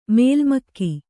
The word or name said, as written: ಮಲಮಗ